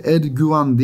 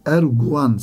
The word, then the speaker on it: erguvan: Bu arada Erguvan değil, Erguvan sevgili dinleyenlerim. U ile bunu da ifade etmek lazım. Çünkü bazen insan yanlış telaffuz edebiliyor. Erguvan denilebiliyor. Erguvan değil, Erguvan. rengi, neşesi, zarafeti ve bütün bunlara mukabil kısacık ömrü.